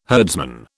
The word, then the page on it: herdsman: British English